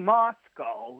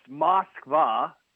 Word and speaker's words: Moskva: Because you keep saying Moscow like you're from in England. It's Moskva. That's what the ship that sank. That's what it is in Russian.